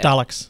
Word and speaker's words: Daleks: Daleks? Daleks. I don't know. I never know how to say it. – Daleks. – Daleks. Thank you.